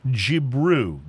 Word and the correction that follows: Jibiru: I'm wrong already. It's Jibiru. – Jibiru? – J-I-B-I-R-U.